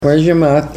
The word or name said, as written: wozhimat